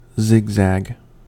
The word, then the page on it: zigzag: American English